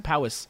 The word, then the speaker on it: Powys: Cowper is spelled C-O-W-P-E-R, C-O-W-P-E-R. – Okay. – And his last name, Powis, is spelled P-O-W-Y-S. Powis.